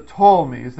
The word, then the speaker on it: Ptolemies: But here, down here in the south, are the Ptolemies. That P is silent.